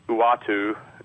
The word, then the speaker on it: Uatu: and it all has to do with Uatu, who I guess that's how you say his name.